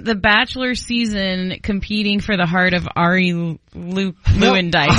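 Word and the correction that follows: Luyendyk: Ari Lyandyke. – Lyandyke. – It was Ari Lyandyke Jr. I've never known how to say it.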